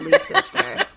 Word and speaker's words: Leicester: It is spelled L-E-I-C-E-S-T-E-R. So I was just looking at it, and I was like, I know that's not Leicester. That'll make no sense. So according to Wikipedia, it's pronounced Leicester Square, and that is where she will be seeing a movie in Leicester Square.